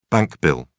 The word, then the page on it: bank bill: British English